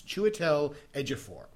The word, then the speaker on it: Chiwetel Ejiofor: But I found his, they actually have the pronunciation on his IMDb page. It's Chiwetel Ejiofor.